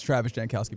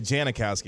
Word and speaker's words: Jankowski: It's Travis Jankowski, by the way. – Yeah, I wasn't going to call you out on adding an extra syllable, but that's okay. – Oh, I said Janikowski, didn't I? – I wasn't going to say nothing about that.